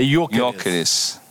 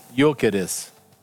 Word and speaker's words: Gyökeres: E eu vi num canal no YouTube ele pronunciando o nome dele, ele não disse que não tem um G. É Gyökeres. – Gyökeres. – Tá vendo? É isso aí.